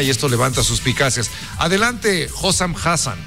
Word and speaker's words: Hossam Hassan: Vamos a escuchar al entrenador egipcio, Hossam Hassan o algo así. – Hossam Hassan. – Hossam Hassan, disculpe.